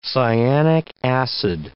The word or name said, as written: cyanic acid